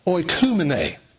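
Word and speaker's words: oikoumene (οἰκουμένη): a word pronounced oikoumene.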